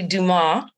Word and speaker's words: Dumas: It's actually Dumas. – Dumas, that's the silent.